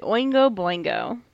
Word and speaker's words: Oingo Boingo: And that was Dead Man's Party by Oingo Boingo.